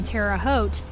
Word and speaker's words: Terre Haute: Terre Haute.